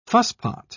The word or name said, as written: fusspot